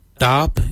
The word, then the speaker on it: dhaab: Dab is spelled D-H-A-A-B.